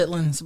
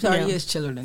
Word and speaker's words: chitterlings: I say chitlins, but it's chitterlins. – That's the correct way to say it. – But it is spelled chitterlings.